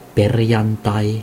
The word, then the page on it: perjantai: [ˈpe̞rjɑn̪t̪ɑi]; /ˈper.jɑn.tɑi/